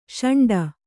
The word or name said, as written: ಷಂಡ